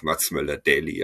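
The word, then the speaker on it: Mats Møller Dæhli: deshalb bin ich jetzt bei dieser äh, Billo-Aussprache geblieben und sage einfach Mats Möller Dali. Aber ich nehme auch Dali, also alles cool.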